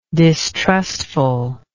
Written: distrustfulness

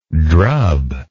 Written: drubber

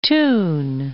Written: Thun, Lake of

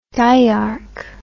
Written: diarch

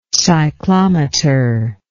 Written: cyclometric

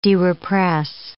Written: derepress

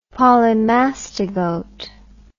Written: polymastigote

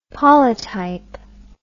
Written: polytype

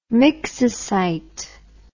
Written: myxocyte

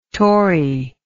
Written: torrey

